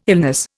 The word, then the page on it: illness: Indian